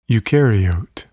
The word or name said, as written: eukaryote